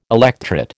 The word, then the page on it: electret: American English